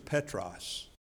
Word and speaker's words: Petros: That word in Greek is Petros. We would spell it P-E-T-R-O-S.